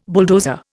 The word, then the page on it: bulldozer: Indian